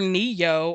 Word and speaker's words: Ne-Yo: There was a rapper Ne-Yo, or is that how you pronounce that? – Yeah.